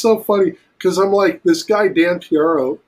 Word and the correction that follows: Piraro: Dan Piraro, who does Bizarro, yes.